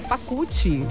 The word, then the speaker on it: Pacucci: Como é que fala ali o sobrenome dela? Sim. Ah, é isso? Não, não é esse nome não. Ah, é Pacucci.